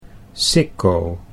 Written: Sicco

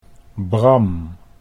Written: Bram

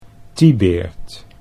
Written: Tibaert

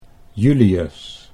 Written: Julius

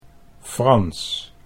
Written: Frans

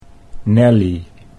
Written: Nellie

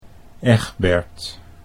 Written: Egbert

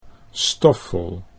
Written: Stoffel

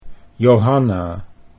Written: Johanna

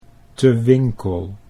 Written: Te Winkel

